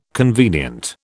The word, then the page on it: convenient: British English